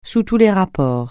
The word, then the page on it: sous tous (les): francais